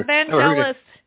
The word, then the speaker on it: Vangelis: Oh, I love Vangelis. – Is that how it's pronounced? Okay. – Yeah, Vangelis.